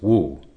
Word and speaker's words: Wu: And when we refer to the Mu koan, the word, the original Chinese, would be pronounced Wu, W-U.